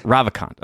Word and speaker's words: Ravikant: Ravikant.